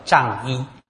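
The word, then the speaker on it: 长衣: 这个在律典里面有一个专用的名词叫藏衣。这个藏哈，就是成长的长或者长短的长哈，但是念作藏。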